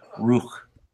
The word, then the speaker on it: Rukh: So you're talking about Ruk, I suppose, which is spelled Rukh.